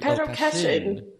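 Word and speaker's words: Pedro Cachín: Am I saying it right? – Pedro Cachin. Yes.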